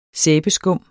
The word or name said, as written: sæbeskum